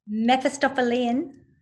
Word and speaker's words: Mephistophelian: Say it again one more time. – Mephistophelian. – And what is that? – From Mephistopheles.